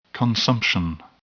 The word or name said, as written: consumption